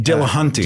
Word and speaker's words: Dillahunty: So how do you say his last name? Dillahunty? – Dillahunty. – Dillahunty, yeah.